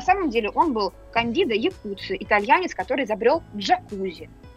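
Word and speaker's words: Jacuzzi: Значит, друзья мои, имя автора, человека, который придумал вот это самое устройство, эту самую конструкцию, это итальянец Кандида Якуцци. Но неправильное произношение, да, на американский манер, он джакузи стал. Если мы с вами прочитаем вот это самое Якуцци, да, в американском варианте, то получится джакузи.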